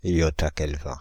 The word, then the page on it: yottakelvin: France (Île-de-France)